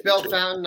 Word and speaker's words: Bellefontaine: Bellefountain. – Now, yeah, is that how you say it? I've never heard anybody say it out loud. – I think it's Bellefountain. I've heard Bellefontaine.